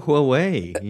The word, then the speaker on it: Huawei: And I was like, please, guys, just learn how to pronounce Huawei. – Just go Huawei, you know?